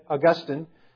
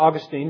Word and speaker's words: Augustine: If you've ever read Augustine, or Augustine, depending on how you like to pronounce his name, he talks about that.